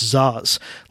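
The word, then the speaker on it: Szasz: check out the works of the late psychiatrist Thomas Zaz. Last name is spelled S-Z-A-S-Z. S-Z-A-S-Z. Thomas Zaz. I think he was from Hungary or something. That's why he has a name that, you know, to Americanize is spelled funny.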